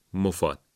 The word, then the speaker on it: مفاد: این واژه اولا تشدید نداره، بعدم میم ضمه داره، یعنی مو، مفاد.